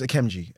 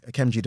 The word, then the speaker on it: Akemnji: No, because what it is, my name's AKEMNJI, AKEMNJI NDIFORNYEN, for people who can't pronounce or don't know. – Can I pause? Because I cannot. – Yes, so AKEMNJI NDIFORNYEN, that's a name from Cameroon.